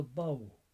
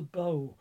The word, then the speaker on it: Bidhbh: She's called the Bough, B-I-D-H-B-H, to spell it in Irish. She tends to be called the Bow in the southeast, ish, that part of the country, whereas most people would regard her as the Banshee.